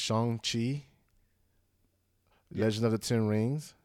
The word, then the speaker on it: Shang-Chi: But yes, apparently it's pronounced Shang-Chi.